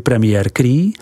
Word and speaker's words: Premier Cru: V jakostních kategoriích nalezneme krom vín statutu AOC také vína Premiere Crus, vyslovuj Premier Cru, která pochází z některé ze dvou desítek vinic určených zákonem pro jejich výrobu.